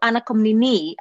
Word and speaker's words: Anna Komnene: So, of course, I knew Anna Komnene, as the Greek pronunciation of the name is.